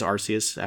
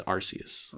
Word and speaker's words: Arceus: So I just wrapped up Pokemon Legends Arceus after about a week and a half. – Arceus? – Legends Arceus. – Okay. – Some people say Arceus, but that's not how it's spelled.